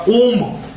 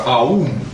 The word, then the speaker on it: Ом: И еще одно такое замечание очень интересное, что дело в том, что мантра «Ом», Ом, известная мантра Ом, или как, знаете, иногда говорят, сладкий Ом, да, или мантра Аум, потому что, знаете, когда вот этот вот переход АО иногда читается как У, АУ читается как О.